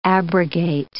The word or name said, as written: abrogate